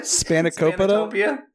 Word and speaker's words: spanakopita: My, my wife keeps buying Spanica to Pipa, Spanica Pipa, or whatever it's called. At how do you say the Greek thing? Spanakopita spanakopita.